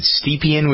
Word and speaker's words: Stepien: It's the story of Jenny, is it Stepien? Would you pronounce it that way, do you think? – That sounds about right, yeah.